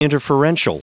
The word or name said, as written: interferential